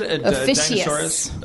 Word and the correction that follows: Ophiuchus: Am I pronouncing it wrong? What is it? Ophiuchus? – I know, right? – Adam Goldberg to the rescue. – Is it Ophiuchus? – Yep. – Okay, Ophiuchus, which is November 29th to December 17th, and I was born on December 5th, so.